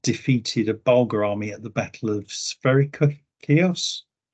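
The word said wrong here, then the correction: Spercheios: That's Sperchieios, in case you were wondering from my pronunciation.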